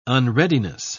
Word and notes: unreadiness: British English

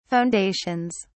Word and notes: foundations: British English

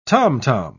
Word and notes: tomtom: British English

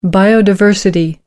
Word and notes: biodiversity: American English

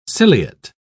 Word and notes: ciliate: British English

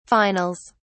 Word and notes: finals: British English

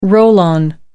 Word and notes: roll-on: American English

